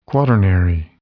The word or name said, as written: quaternary